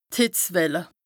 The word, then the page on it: d' Hìtzwelle: Bas-Rhin; Strasbourg